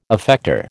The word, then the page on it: effector: American English